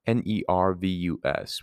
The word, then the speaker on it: nervus: Nervous is actually spelt nervous, but it's pronounced nervous. Excuse me. Nervus, which refers to sinew or nerve.